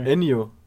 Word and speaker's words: Ennio: Ennio.